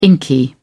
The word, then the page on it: inky: British English